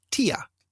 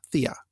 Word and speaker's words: Thea: The mother of a childhood classmate, he says, was a German immigrant named Thea, and she always pronounced her name Tia, not Thea. So he says, I've always read Thecla and Thea with a hard T instead of a soft TH.